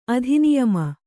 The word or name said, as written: ಅಧಿನಿಯಮ